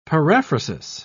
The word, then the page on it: paraphrasis: British English